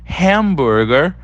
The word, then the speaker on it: hamburger: Em seguida, nós temos o hamburger. Hamburger. Repara como é diferente. A gente fala hambúrguer e lá eles falam hamburger, tá?